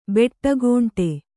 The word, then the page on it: ಬಿಡುಗಂಡಿ: biḍugaṇḍi